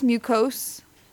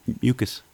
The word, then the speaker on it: mucus: Milk does not increase mucose. – Mucus.